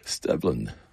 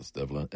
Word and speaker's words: Stevland: And we kicked off with the man himself, the birthday boy, Stevland, I think that's how you pronounce it, Stevland, S-T-E-V-L-A-N-D, Brother Kelly.